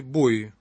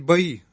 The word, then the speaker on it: бои: Что такое барабанные бои? Можно говорить бои, но правильнее, как мне кажется, это спорно, но мне кажется, нужно делать ударение на О. Барабанные бои, потому что это не бои, это бой барабанных палочек.